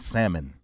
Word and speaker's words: salmon: Real nigga this particular podcast, since I'm on it, this for all the women that pronounced the L in salmon.